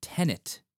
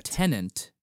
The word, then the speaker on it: Tenet: But with that, please be sure that you're pronouncing it Tenet, not Tenant, because Tenant is not a palindrome.